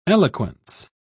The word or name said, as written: eloquence